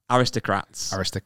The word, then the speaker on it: aristocrats: Aricostrax. – No, that's not right either. Aristocrats.